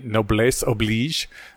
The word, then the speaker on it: noblesse oblige: Yeah, I think this fits into our discussion about the noblesse oblige. Apparently, I was mispronouncing it the whole time because unlike every other French word with S's, and this one you actually pronounce the S.